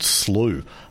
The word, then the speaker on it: Slough: And indeed, that's where this study was done, at a place called Elkhorn Slough. Slough is S-L-O-U-G-H, so it's pronounced as in through.